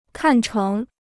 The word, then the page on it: 看成: kàn chéng